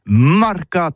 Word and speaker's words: marcats: Eh bien, d'ici à toutis, le mercat de l'Uni ou le mercat des rebelles, mais en gascogne toulousain, c'est prononcé sur les marcats, avec un A marcat.